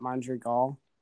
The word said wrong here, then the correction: Madrigal: Nick, Madrigal.